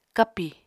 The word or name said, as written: kapi